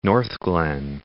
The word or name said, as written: Northglenn